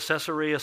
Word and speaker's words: Caesarea: He said, all right, why don't you pronounce it correctly? So I've always called it Caesarea.